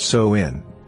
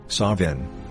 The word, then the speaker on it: Samhain: Samhain, pronounced So-in, Sovin, or Samhain, means end of summer and is the third and final harvest.